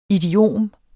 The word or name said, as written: idiom